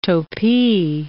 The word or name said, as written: topee